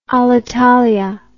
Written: alitalia

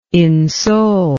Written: insoul

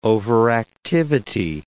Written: overactivity

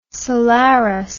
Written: solaris

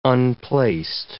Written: unplaced

